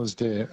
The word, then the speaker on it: Alasdair: Alasdair, A-L-S, and then D-A-I-R.